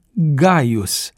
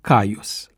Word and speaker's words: Caius: În ceea ce privește numele Caius, acesta se pronunța Gaius, dar se prescurta cu litera C, pentru că așa cerea vechea regulă a prescurtării. De aceea s-a ajuns mai târziu la pronunția Caius.